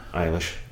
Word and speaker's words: Eilish: Eilish. No, yeah, because I always think it sounds like eyelash. – Okay. – Billie Eilish.